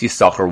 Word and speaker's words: Yissachar: and the Devek Tov all say that you should pronounce Yissachar with one sin, like Ben Asher and not like Ben Naphtali.